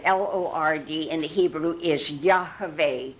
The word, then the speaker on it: Yahweh: It's the Hebrew Hebrew alphabet, Y-H-V-H. By the way, we studied the Hebrew alphabet on Wednesday night. Y-H-V-H is pronounced Yahweh. Want to say it? Yahweh. Okay, it's not Yahweh and it's not Jehovah.